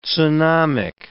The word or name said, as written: tsunamic